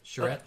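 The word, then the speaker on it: charrette: There's a design, Charette. Did I say it right? – Yep, Charette.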